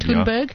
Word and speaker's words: Thunberg: Thunberg? – Thunberg has hit back at Donald Trump after he tweeted telling her to chill.